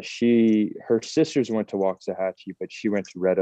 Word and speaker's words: Waxahachie: Waxahachie.